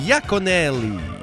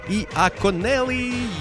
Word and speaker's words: Iaconelli: Danny, j'avais mal prononcé son nom. J'avais prononcé Danny Lacunelli, mais c'est un I au début. Et un I majuscule puis un L, ça se mélange, alors c'est Danny Iaconelli. Iaconelli.